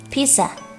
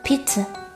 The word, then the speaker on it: pizza: Pizza 这个单词虽然很常见，但容易读错成 pizza，要注意音标中有个轻轻的。音读作 pizza。